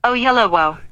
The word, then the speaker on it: Oyelowo: No, that's not how you pronounce it. It's David Oyelowo. – Yeah, that's what I said. David Oyelowo. That's right. David Oyelowo is going to be the Bass Reeves story.